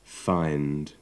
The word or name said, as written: find